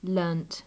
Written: learnt

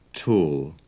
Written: tall

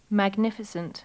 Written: magnificent

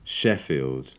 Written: sheffield

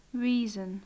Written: reason

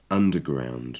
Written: underground

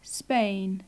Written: spain

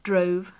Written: drove

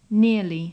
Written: nearly

